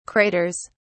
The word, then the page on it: craters: British English